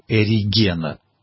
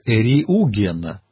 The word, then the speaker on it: Эриугена: Вот Бриллиантов пишет именно так, как до революции произносили его фамилию Эригена. Вот сейчас все-таки есть традиция произносить и писать как Эриугена, а у Бриллиантова это Эригена.